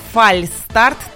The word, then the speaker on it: фальстарт: Так и тянет в это слово добавить буквы «Ш» и мягкий знак. Вот, сдержите свои порывы, лишние буквы там совсем не нужны. Слово «фальстарт» так и пишется и произносится без буквы «Ш».